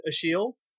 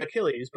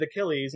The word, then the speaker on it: Achille: His name is spelled, it looks like Achilles, but it's pronounced Achille. And so I thought it was kind of funny. There's multiple narrators reading the book, and so some of them pronounce it Achilles and some of them pronounce it Achille.